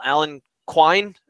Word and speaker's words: Quine: And Mr. A guy that we have not talked about at all, Alan Quine, is that how you say his name? – Yep, that's it.